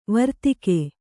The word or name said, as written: ವಾರ್ತಾಕ